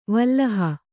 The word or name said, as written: ವಲ್ಲಹ